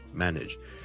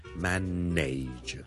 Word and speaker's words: manage: manage, M-A-N-A-G-E, manage。由於這個字後面 N-A-G-E, nudge, 是串做 N-A-G-E, 很多人就會將它讀成 manage。但事實上呢，我這個字是讀 manage, nudge, nudge, nudge。你當是好像 N-I-D-G-E 那樣。